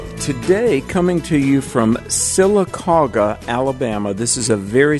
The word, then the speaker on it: Sylacauga: if I'm pronouncing this correctly, Sylacauga, Alabama. – You got it. That's how you say it. Even in Amen. – Alabama, you did good.